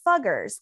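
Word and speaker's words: Fugger: Is that how you say it? – Yes, it's the Americanized. Like, probably at the time it was Fuggers.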